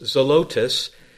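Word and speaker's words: zelotes (ζηλωτής): The word zealot here is the Greek word, which can be pronounced zelotes, and it speaks of a man of an earnest and an intense, zealous personality, a man of a fiery, passionate character.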